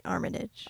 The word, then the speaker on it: Armitage: The director was George Armitage? – I say it like the Chicago way. Armitage.